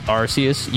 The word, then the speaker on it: Arceus: First of all, it's pronounced Arceus, you uncultured swine.